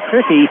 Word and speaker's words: Krichke: Oh, Cricky, I mispronounced it because I misspelled it, K-R-I-C-H-K-E, Cricky, is serving for the Comets.